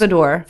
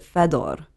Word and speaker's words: Fedor: So how do you say your last name then? If it's Polish, I think it's... – Fedor. – Fedor, okay. – Yeah, I think in my family in Poland that lives there, or if my dad had his way, he would pronounce it Fedor.